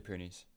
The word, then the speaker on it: Pyrenees: There was, I can't even pronounce it, the French The Pyrenees. – The Pyrenees Games.